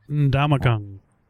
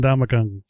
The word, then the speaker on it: Ndamukong: and it's also looking bad on Ndamukong Suh. I don't believe I'm pronouncing that correctly, but... – Ndamukong. – Yeah, there you go. – It starts and ends with the same sound, Ndamukong.